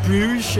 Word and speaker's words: Bruges: Bruges, how do you say that? – In English it's Bruges. In German it's Brugge.